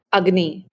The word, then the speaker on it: Agni: Is it pronounced Agni? – Agni. – Agni. Okay, Agni.